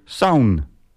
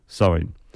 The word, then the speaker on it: Samhain: Halloween, wat over het algemeen altijd wordt gezegd, het komt van het Keltisch Samhain. Of zoals op de Nederlandse Wikipedia pagina staat, uitgesproken Saun. S-A-U-N. Niet waar, Samhain. Maar goed, misschien is dat heel subtiel.